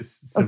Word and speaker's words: Sevigny: How do you say her surname? I love her. – You did it. Sevigny.